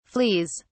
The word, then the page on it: fleas: British English